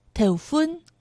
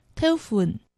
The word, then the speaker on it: 头份: 在今天节目中我们要探访的是苗栗县头份市，四县腔发音头份，海陆腔发音头份。